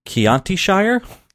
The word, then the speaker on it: Chiantishire: And we're here today to talk about episode 8 of season 3, Chiantishire.